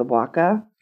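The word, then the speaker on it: bwca: Comes from the knocker, the knacker, the waka.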